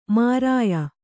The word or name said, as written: ಮೈರೇಯ